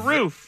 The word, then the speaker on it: roof: While people born and raised in the West tend to pronounce the word as if it rhymes with hoof, in other words, roof, those from the East see it as rhyming with poof, as in roof.